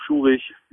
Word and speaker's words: Schurig: Auf Schurig, wie ich gelernt habe, so wird er wohl korrekt ausgesprochen.